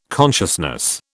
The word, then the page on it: consciousness: British English